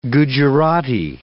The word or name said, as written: Gujarati